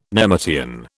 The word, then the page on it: nemertean: British English